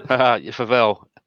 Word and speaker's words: Favelle: And welcome to the podcast, Greg Favell. Is that how I pronounce it? I should have asked that before we started. – Favell. – Favell. I was going to go with that, and I'm like, there's an E on the end.